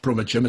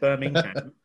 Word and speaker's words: Birmingham: Teach you how to pronounce Birmingham, because there is a G in it. Or Brummagem, as I would say.